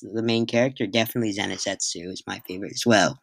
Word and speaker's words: Zenitsu: I'm really bad at pronouncing names, but I would say Zenitsu, isn't it? – Zenizetsu, yes.